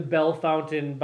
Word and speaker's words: Bellefontaine: we can always continue to call it Bellefontaine, Ohio. – Sure, you can. – But it's the Bellefountain Bearders. – Because that's how they pronounce their name.